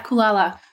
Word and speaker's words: Kulala: How do you pronounce it? Kulala? – Yeah, Kulala. Kulala means, Kulala sounds really cute. And it's also sleep in Swahili.